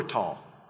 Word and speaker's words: tall: As you look at it from more of a map view, there's an upper tall that you just saw. That's the high point. And then the lower tall. We call it tall, not tell, because the Arabs pronounce it tall in Jordan.